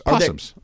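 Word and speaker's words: opossum: We've got a family of, I think they're called opossums. – Possums. I think I believe the O is silent. – Is the O silent? I believe it is. Opossums. Family of opossums. – Yes, I believe it's silent.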